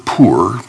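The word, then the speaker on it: pur (πῦρ): And the word fire, it's the word pur, pronounced just exactly like we would pronounce poor.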